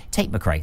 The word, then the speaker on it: McRae: Tate McRae. That's right. Yeah, that's how you say her name.